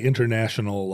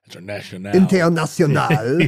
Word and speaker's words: international: Well, you don't say international like that in French. You just pronounced it in the Spanish form. International. – International.